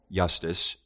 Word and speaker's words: Iustus: Iustus, which it begins with an I, is pronounced with a Y, but yet corresponds to our J, refers to being just or righteous.